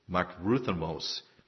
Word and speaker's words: makrothumos: The word that is used here for long-suffering, I'll try to pronounce it, is makrothumos. It's M-A-T-R-O-T-H-U-M-I-S. Makrothumos.